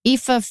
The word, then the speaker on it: Aoife: I loved Aoife. Aoife from Instructions from a Heatwave. Am I saying her name right? – That's right. Aoife.